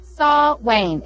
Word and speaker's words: Samhain: Did I say it right? Kim told me last time. Sawhain. It's S-A-W. Sawhain. I can never remember that. That's actually the correct pronunciation.